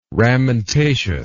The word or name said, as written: ramentaceous